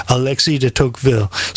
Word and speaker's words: Alexis de Tocqueville: Proper pronunciation is Alexis de Tocqueville.